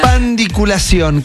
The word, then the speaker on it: pandiculación: Pandiculación. ¿Qué es la Intramitrosón. – ¿Qué es la pandiculación? Con acento en la O final. Es la única O, ¿no? – Es como unos bichos en el estómago.